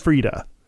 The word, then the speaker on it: Freedia: Frida. Silent I. – I think it's Big Frida. – Big Frida. Okay. Spelled F-R-E-E-D-I-A. – Okay. – The silent I, because it's all about you.